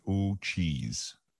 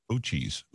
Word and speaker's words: Uchis: Callie, and it says to pronounce it Ochees. Ochees. It's spelled Uchis.